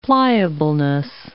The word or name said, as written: pliableness